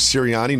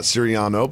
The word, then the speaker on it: Sirianni: We should also recognize the Eagles head coach is Sirianni, not Sirianno. But that's basically the same.